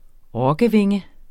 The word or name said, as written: rokkevinge